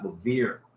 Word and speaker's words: Levere: Today we've got Levere. I'm saying that right, right? It's almost like too obvious that I want to make sure I'm saying it right. – You got it, man.